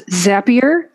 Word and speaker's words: Zapier: According to their Twitter, how do you pronounce Zapier? It rhymes with happier.